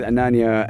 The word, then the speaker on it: Ananya: We are with Ananya, and am I saying the name right? – Ananya, yeah.